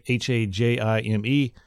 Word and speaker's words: Hajime: So we've got some new malware out there called Hajim, probably not even close to pronouncing that right, Hajime.